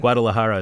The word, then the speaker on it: Guadalajara: The torta ahogada is a sandwich with almost mystical restorative powers, or at least that's what they believe in Guadalajara. How do you pronounce that? – Guadalajara.